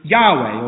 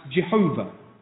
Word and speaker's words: Yahweh: And he says, go and tell Israel, the Lord, where it says there, the Lord in capitals, that's the Hebrew Yahweh, or sometimes pronounced Jehovah.